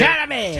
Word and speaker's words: Chalamet: How do you say his name? – Chalamet.